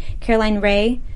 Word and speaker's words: Caroline Rhea: is hilarious, and Caroline, I don't know how to pronounce her last name, Caroline Rhea from yes.